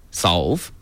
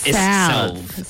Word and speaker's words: salve: Solve. – Yeah. – B-L-A-C-K. – Not solve like solving a problem. It's salve.